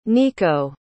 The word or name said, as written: neco